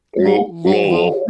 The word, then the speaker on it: Louisville: You got to say that right, too. – Louisville. I'm from Columbus, yeah. Ohio. I know how to say it.